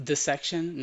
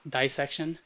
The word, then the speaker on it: dissection: Yeah, apparently it's pronounced dissection, not dissection.